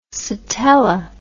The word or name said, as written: sitella